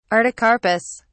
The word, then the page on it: artocarpus: British English